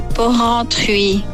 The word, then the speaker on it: Porrentruy: E Porrentruy. – Porrentruy, aspetta che risentiamo. – Porrentruy e per uscire. – Trova qua sexy. Aspetta. – Jeunet e Porrentruy.